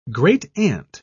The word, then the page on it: great-aunt: British English